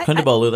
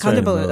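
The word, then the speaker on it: Kondabolu: Yeah, Kundabolu. That's Kundabolu.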